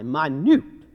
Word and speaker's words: minute: It is pronounced not like minute steak, but with a long I, minute.